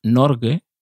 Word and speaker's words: Norge: Esto, esto empieza ahora, porque Noruega, Javier, Noruega, en noruego Bokmal se pronuncia Norge, en noruego Ninorsk, Norek, y en Sami septentrional Norga.